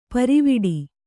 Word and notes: ಪಾರಿವಟ್ಟೆ: kannada

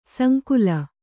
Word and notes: ಸಂಕುಲ: sankula

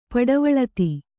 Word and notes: ಪಡವಳಿತಿ: paḍavaḷiti

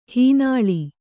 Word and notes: ಹೆಣ್ಣಾಳು: heṇṇāḷu

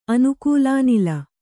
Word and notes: ಅನುಕೂಲಾನಿಲ: Kannada

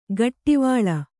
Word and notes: ಘಟ್ಟಿವಳ: ghattivaḷa

